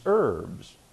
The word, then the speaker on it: herb: He eats herbs, which is how I pronounce it. I don't pronounce the H. Herb to me was a relative. I may be wrong, but to me it's an herb.